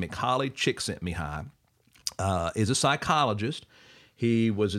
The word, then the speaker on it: Csikszentmihalyi: Mihaly Csikszentmihalyi. And phonetically, his last name sounds like Chick, Scent, me high.